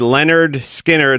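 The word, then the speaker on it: Lynyrd Skynyrd: Now, I always said Leonard Skinner like Leonard Cohen or Leonard Nimoy.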